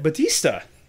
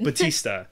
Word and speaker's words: Bautista: Everybody, welcome, Bernadette Batista.